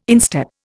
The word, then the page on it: instep: Indian